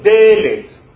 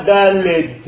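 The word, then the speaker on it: dalet (דלת): Regardez, le dalet s'écrit comme dalet. C'est la même chose. On ne dit pas dalet avec un d à la fin comme j'entends souvent. C'est dalet, c'est-à-dire dalet, une porte.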